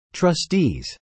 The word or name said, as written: trustees